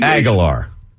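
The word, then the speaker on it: Agholor: Aguilar. – Yeah, Aguilar. You did it too. It's not A-R, it's O-R.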